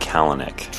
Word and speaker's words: Kalanick: Last name's pronounced Kalanick.